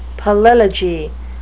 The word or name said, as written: palilogy